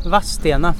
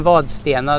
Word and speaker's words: Vadstena: Vadstena, med akut accent. Va? och inte något det. Då säger man Vadstena, då har man direkt ett stigmata som utsocknes.